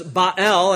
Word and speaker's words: Baal: And what does Baal, as his Hebrew pronunciation is, what does he claim to be?